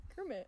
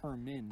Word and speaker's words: Kermit: Can I get a double take on how you pronounced Kermit? – Kermit. – Okay. – What did I say? I heard Kermit. What? What? How else would I say Kermit? – You, you said it correctly now. – But what did I say before? – Kermin.